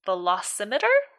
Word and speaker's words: velocimeter: There's a little red and black indicator on the velocimeter. Did I say that right? – Yes.